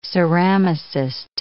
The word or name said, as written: ceramicist